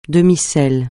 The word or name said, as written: demi-sel